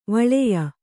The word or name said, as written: ವೀಳೆಯ